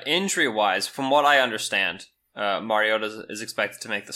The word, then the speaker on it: Mariota: I know there are very vocal people about the pronunciation of his name, which is supposed to be Mariota, but whatever.